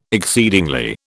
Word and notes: exceedingly: British English

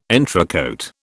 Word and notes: entrecÓte: British English

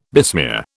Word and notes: besmear: British English

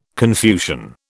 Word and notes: Confucian: British English

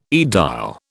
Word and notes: aedile: British English

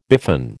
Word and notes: biffin: American English

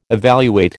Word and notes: evaluate: American English

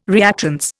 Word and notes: reactance: Indian

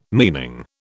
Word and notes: meaning: British English

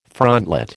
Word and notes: frontlet: American English

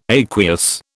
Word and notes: aqueous: British English

